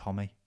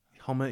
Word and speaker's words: Homme: I'd always called him Homme for years, but apparently it is pronounced Homme. – Homme, yeah.